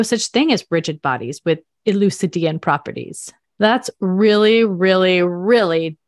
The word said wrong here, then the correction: Euclidean: oh no, I said that wrong. Not Elucidian. Okay. Let me say one more time. okay. In gravitational fields, there are no such things as rigid bodies with Euclidean properties.